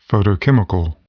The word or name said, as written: photochemical